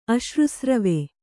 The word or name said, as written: ಅಶ್ರುಸ್ರವೆ